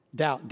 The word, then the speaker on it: doubt: Doubt, D-O-U-B-T. Where do you get a B from? D-O-U-B-T, doubt? I would spell it D-O-W-T, doubt. The reason it's D-O-U-B-T is because it comes from another word, which is double.